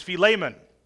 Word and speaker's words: Philemon: And I want to tell you right up front that I pronounced this name incorrectly. The proper pronunciation is Philemon.